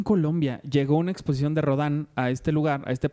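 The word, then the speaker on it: Rodin: Pero que una que se llama, por cierto, Matar a Rodán, que yo le decía Rodín porque se escribe Rodín, pero es Rodán, que es creo Rodán.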